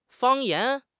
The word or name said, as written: 方言